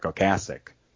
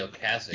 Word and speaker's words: Ocasek: But, you know, I used to say in the early days, Ocasek, too. But then I think what changed that with MTV, when he'd say, hi, I'm Rick Ocasek. And he'd actually pronounce his name correctly.